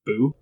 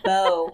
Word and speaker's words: beau: Isn't it pronounced boo? – No, her beau. Oh, okay. Like her gentleman caller.